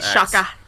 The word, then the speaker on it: Granit Xhaka: Granit Xhaka? I can't pronounce names, especially not ones at the beginning. – Granit Xhaka.